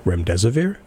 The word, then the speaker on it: remdesivir: The doctor can correct me here because uh, I'm gonna mispronounce it. Remdesivir. – You got it.